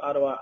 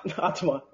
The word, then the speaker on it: Ottawa: What made you guys, you know, want to go to Ottawa, Iowa, and help Walter and Twin Galaxy and get, you know, that part of history? – Well, Ottawa's in Canada. – Oh, Ottawa.